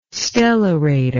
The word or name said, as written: stellarator